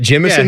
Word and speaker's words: Jemison: How do you say that? Jemison? Yeah. Like Jimothy? – Yeah, he was in Ocean's 11.